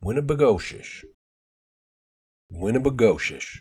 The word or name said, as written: Winnibigoshish